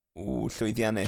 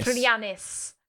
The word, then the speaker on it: llwyddiannus: llwyddiannol. Have I said that right? – Llwyddiannus. – Llwyddiannus.